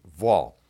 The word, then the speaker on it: waw: Now the Hebrew word for and here is vaw, spelled W-A-W. It's pronounced with a soft V, vaw.